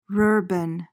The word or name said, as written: rurban